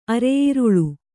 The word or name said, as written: ಅರೆಯಿರುಳು